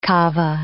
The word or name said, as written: kava